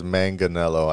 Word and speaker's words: Manganiello: I think it's Manganiello. I said Manganiello last time and Manganiello. Dave corrected me.